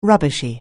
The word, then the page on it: rubbishy: anglais